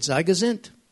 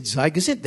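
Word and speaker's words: zei gezunt: I said, Zygazint. Zygazint, which means be, be healthy, be well. But in Hebrew, they pronounce it differently. So I said to them, I said, Zygazit.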